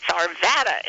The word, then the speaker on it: Arvada: And they would say, no, no, no, it's Arvada, it's Arvada. And that's the way they pronounce the town.